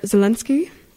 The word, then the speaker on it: Zelensky: And then in 2019, there is the Ukrainian president, Volodymyr Zelensky.